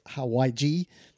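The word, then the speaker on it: hawaij: It looks like Hawaii, but it's not. It's Hawaii-G. – Hawaji.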